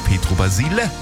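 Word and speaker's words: Pietro Basile: Er hat mich berichtigt beim Schlager-Olymp. Ich heiße Petro Basile.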